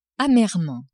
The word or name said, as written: amerement